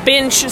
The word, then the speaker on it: Binch: And that is Binch, spelled B-I-N-C-H. – Rhymes with the Grinch.